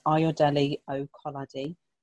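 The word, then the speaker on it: Ayodele Okolade: So my handle is Ayodele Okoladi, and that's spelled AYODELEOKOLADE.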